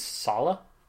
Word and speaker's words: Saleh: I'm going to mispronounce the name of the defensive coordinator, Robert Saleh. – I think it's Saleh.